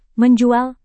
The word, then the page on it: menjual: Indonesian